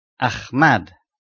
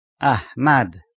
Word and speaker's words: Ahmad: Both are pronounced with the breath sound of he, he, as in entry number 17, Ahmad, which occasionally is mispronounced Ahmad. I repeat the correct pronunciation, Ahmad.